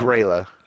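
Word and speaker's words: Grýla: That's Grela. – Oh, man, it doesn't look like it. – That's how it's pronounced.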